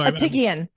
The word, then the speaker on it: Apigian: Even though Apigian. It's a hard G.